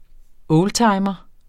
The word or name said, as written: oldtimer